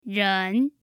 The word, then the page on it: ren: 2